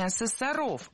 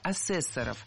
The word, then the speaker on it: асессоров: Лучше сказать не так, не асессоров, а асессоров. В слове «асессор» ударение на втором слоге сохраняется во всех формах. Асессоры, асессоров, асессором. Современный асессор – это специалист, который оценивает качество работы алгоритмов, систем.